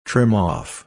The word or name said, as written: trim off